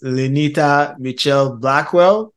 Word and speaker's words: Lynita Mitchell Blackwell: Her name is Lynita Michelle Blackwell.